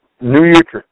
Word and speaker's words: New Utrecht: It's also called New Utrecht. Is that how you pronounce it? – New Utrecht, yes.